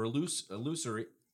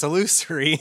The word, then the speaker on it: illusory: Although true love proves illusionary or illusory. How do you say that word? – That's illusory. – Illusory.